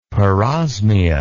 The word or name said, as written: parosmia